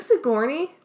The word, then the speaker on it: Sigourney: So, some comments on Sigourney Weaver. She looks real good in this movie, let me just say. – Is it Sigourney? – Yeah.